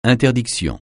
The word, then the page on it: interdiction: francais